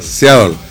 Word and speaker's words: Seattle: Seattle. – Bueno, yo dijo alguien. – Ah, pero le repito, eso, yo no. – ¿Dónde voy? A ver, ahí, aquí ve que sabe. Seattle.